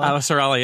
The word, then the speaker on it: Avasarala: So my favorite character in the entire series so far is a narrator from book two, who is, and I'm going to mispronounce her name, but Avasarala? – Avasarala, yeah. Yeah, right.